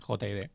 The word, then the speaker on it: J.I.D.: a ver si lo digo bien en inglés, J.I.D. – Sí, J.I.D. – Así va la cosa, ¿no?